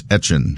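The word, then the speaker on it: Etienne: go by the pronunciation that they would say in Louisiana, which always was like Etchin. – So it's Travis Etchin.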